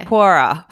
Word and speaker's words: Quora: So it was actually Quora. Is that how you say it? I always wonder Quora. – Yeah, this Q-U-O-R. Yeah. – Quora. So an entomologist answered this question.